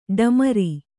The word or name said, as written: ಢಮಾರ್